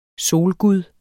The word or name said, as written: SOLGUD